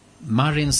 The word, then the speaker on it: Marin: Men hon, Sanna Marin, säger jag därför att jag hörde en korrespondent i Ekot, Erika Gabrielsson, uttalade henne precis så. Och sen hade jag kontakt faktiskt med en språkvårdare om just uttalat det här. För jag vill veta, heter hon Marin eller Marin? För andra säger Sanna Marin nämligen.